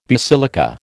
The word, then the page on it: basilica: American English